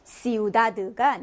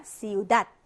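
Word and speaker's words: ciudad: 시우닷 하고 읽었죠. 자, 여기 대가 맨끝 철자로 올 때, 자, 이럴 때는 받침화해서 읽어줍니다. 그래서 시우다드가 아니라 시우닷 하고 읽어주거든요.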